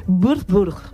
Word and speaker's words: Würzburg: Ahí hay una ciudad que esta sí que es la más importante, pronunciable, te lo prometo, reconocido por los alemanes, es Würzburg. La semana que viene practico más. – No te ha salido mal.